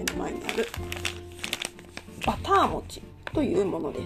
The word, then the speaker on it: 牛酪餅: の酪餅と書いて牛酪餅バター餅っていうふうに読み仮名が振ってあります